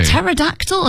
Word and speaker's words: Pterodactyl: Pteranodon, which is surprisingly difficult, because it's got a silent P at the beginning of it. Pterodactyl, another silent P.